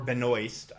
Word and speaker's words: Benoist: opposite of Melissa Benoit playing, or Benoist, I guess actually is how it's pronounced, playing Chronic.